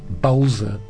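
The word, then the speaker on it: Bölzer: The word's got an umlaut over the O, so two dots over the O. – Oh, okay, yeah. – So you pronounce it Belzer or Bolzer. It would be the Bolzer. Anglicised version of saying.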